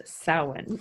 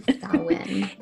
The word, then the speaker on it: Samhain: It looks like Samhain, but it's pronounced Samhain. – Samhain.